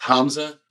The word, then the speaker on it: Hamzeh: It's pronounced Hamza.